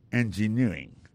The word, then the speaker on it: engineering: Engineering, ça, l'accent tombé le même sur avant dernier syllabe là.